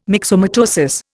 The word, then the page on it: myxomatosis: Indian